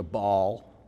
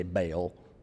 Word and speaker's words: Baal: If you worshipped Moloch or you worshipped a Baal, we usually say Baal. I might say Baal. I might pronounce it both ways.